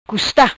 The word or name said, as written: Custa